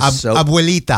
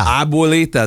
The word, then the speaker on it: Abuelita: I never heard of I'm going to butcher this. – Abuelita. – Abuelita, that's her. Yeah. Yeah, I can't say it, but my wife's going to kill me right now, dude. – Abuelita, you got it.